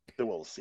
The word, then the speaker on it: Thewlis: David Thewlis. – Yes. Thewlis, yes.